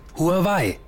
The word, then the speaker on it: Huawei: Huawei. – Wie bitte? – Huawei. – Wie schreibt man das denn? – Na, wie man es spricht. Huawei. H-U-A-W-E-I.